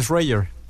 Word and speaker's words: Schreier: ¿Sí se pronuncia así? – Schreier, más bien Schreier.